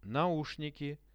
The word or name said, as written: naushniki